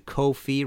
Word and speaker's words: Ko-fi: If you like this show and want to support future episodes, please go to Ko-fi rhymes with no fee uh, dot com slash Martin Popoff.